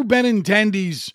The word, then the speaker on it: Benintendi: They roll out a starting nine with Andrew Benintendi's, I said it right that time, his 270 BA was the best in their starting lineup yesterday.